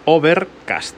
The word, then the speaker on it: Overcast: Overcast, así, tal cual se escribe.